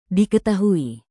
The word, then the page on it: diketahui: indonesian